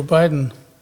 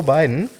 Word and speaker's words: Biden: Das Geile in Zukunft ist, wenn Joe Biden mit seiner... – Heißt der Biden oder Bidden? Biden will ich aussprechen, oder? – Ja. Egal. Ich höre immer nur Biden. Ja.